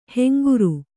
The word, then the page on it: ಹೆಂಗುರು: henguru